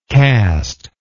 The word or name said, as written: casteless